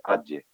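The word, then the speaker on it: Agde: Y luego de ahí es destinado al campo de Adge, se escribe Adge, Adge, se dice Adge.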